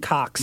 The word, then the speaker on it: Koch: Am I pronouncing that right? – Yeah. I just call them Cox.